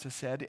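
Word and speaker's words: hesed (חסד): The Hebrew word for kindness is the word hesed. It's pronounced hesed, and it's defined as loyalty.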